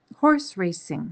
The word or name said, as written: horse racing